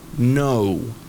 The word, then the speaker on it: know: es know, know, como no.